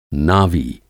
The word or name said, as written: नावी